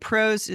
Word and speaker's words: Prose: And PROS is spelled P-R-O-S-E.